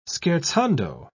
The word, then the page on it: scherzando: British English